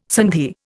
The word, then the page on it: sandhi: Indian